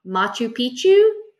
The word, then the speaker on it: Machu Picchu: And we're going to go climb, I'm going to say this wrong, Machu Picchu? – I think you said it right.